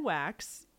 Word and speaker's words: Waks: His name is Ken Wax. It's spelled W-A-K-S though, but it's pronounced Wax. But yeah, I kept thinking okay. his last name was like W-A-X. W-A-X, mm-hmm.